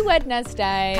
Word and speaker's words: Wednesday: It's a silent D. Wednesday. – Silent D, yeah. – Yeah, because otherwise it would just be W-E-N-S-D-A-Y.